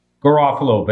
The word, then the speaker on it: Garofalo: Garofalo.